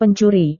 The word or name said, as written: pencuri